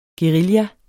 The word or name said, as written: guerilla